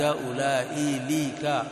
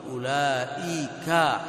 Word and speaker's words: أولئك: ذا أولئك أولئي ليك لا ينطق به، لا تأتي باللام فيه.